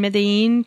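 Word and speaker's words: Medellín: Well, Luis was born in Medellin, Colombia. See, I said that right. And there were no R's for me to roll and show off, though.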